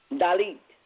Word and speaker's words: Dalit: Is it Dalit? Dalit? How do you mm-hmm. pronounce it? Dalit. Dalit. Okay.